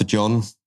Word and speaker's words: Jon: It stands for John, but it's spelt J-O-N without the H, so it's different.